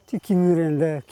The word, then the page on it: kirirende: ki.ri.ren.de